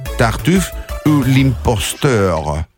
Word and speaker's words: Tartuffe ou l'imposteur: Tartuffe ou l'imposteur. Έτσι διαβάζεται. – Πολύ καλά το πες, μπράβο.